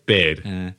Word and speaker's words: Baird: Do you think his name is Bard or Baird? – Baird.